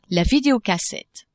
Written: videocassette

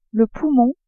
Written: poumon

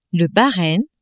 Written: Bahrein

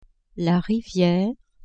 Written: riviere

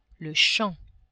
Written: champ

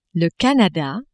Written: Canada

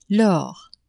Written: lors

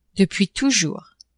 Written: depuis toujours

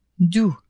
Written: d'ou

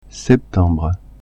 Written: septembre

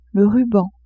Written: ruban

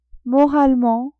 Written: moralement

